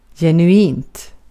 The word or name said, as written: genuint